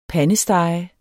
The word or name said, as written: pandestege